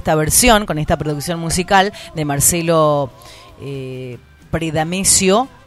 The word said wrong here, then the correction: Predacino: Preda, sino Predacino ahí lo dije bien, a través de, Radio Nacional.